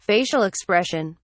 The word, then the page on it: facial expression: British English